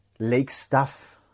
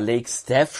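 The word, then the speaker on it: Lake Staff: Lake Stuff. – Okay, was bedeutet das? – Aber mit A tatsächlich. Also eigentlich war Lake Steph schon richtig ausgesprochen, aber wir sagen trotzdem Lake Stuff, also mit S-T-A-Doppel-F. Lake Stuff heißt im Prinzip Seemannschaft, mehr oder weniger.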